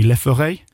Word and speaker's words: Le Furet: If, on, how do I say it? Say it right for me. Le Furet. Le Furet.